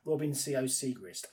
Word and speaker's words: Robin Sciot-Siegrist: Um, and finally, um, another another Frenchman, Robin C.O. Segrist, get his name no, pronounced correctly, Robin Sciot-Siegrist, 80 to 1.